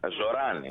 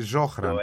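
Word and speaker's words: Zohran: Ναι, βεβαίως, εννοείται ότι ψήφισα τον Ζωράν, είναι το σωστό. – Το H δεν προφέρεται, γιατί το έχω διαβάσει Ζόχραν, το έχω διαβάσει Ζωχράν, το έχω διαβάσει όπως να είναι.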